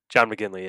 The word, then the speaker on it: McGinley: John McGinley, yeah.